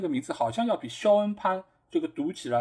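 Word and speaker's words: Sean: 但是其实这个读法应该是叫肖恩，对吧？这个 S E N 这个词其实一直就是被读作肖恩的。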